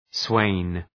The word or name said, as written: swain